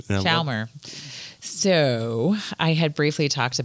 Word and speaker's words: Chalmer: You're close. – Can you pronounce? – It's Chalmer. – Chalmer. Yeah. – You kind of like skip over the L a little bit. Chalmer.